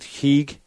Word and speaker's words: Higue: Steve, they actually think in that mythology that the creature that's the vampire, I guess it's pronounced the old Higue, H-I-G-U-E, mm-hmm.